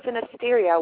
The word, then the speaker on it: Synesteria: Tonight our special guest is author Andrea Dean Van Skoyak, and she is an award-winning author of Horror Synesteria, which I hope I'm pronouncing better than I think I am. Am I pronouncing that right, Andrea? – You did fine.